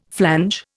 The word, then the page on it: flange: Indian